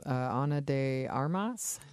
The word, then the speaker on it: Ana de Armas: We also have Chris Evans, Ana de Armas.